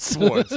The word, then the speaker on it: Swords: Swords. You have to say it like RZA says it.